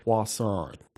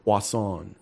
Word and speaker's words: Poisson: We butchered his name. It's Robert Poisson. – Poisson? – Poisson. – Oh, is he like French? – It's kind of like French, yeah.